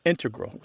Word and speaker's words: integral: Just to clarify here, both correct, but the mathematicians and math-using scientists are more likely to put, say, integral with the stress on the first syllable.